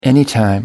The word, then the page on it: anytime: American English